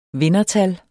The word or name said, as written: vindertal